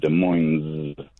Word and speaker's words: Des Moines: And here in Washington, we say a Z sound at the end. Des Moines.